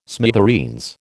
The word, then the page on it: smithereens: American English